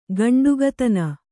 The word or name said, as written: ಗಂಡುಗತನ